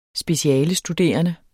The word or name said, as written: specialestuderende